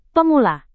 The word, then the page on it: pemula: Indonesian